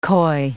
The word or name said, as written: koi